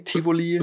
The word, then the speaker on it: Tivoli: Tivoli.